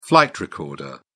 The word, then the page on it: flight recorder: British English